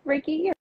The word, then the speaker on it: Reiki: So I really wanted to bring you on a live call and introduce my followers to veganism and also talk about Reiki healing. Is it pronounced Reiki or is it something else? – No, Reiki.